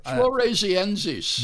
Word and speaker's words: floresiensis: In addition, the origins of Homo, and how do you say that? Because they're Floresiensis.